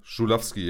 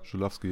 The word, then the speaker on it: Żuławski: Żuławski, ja. – Żuławski, ja.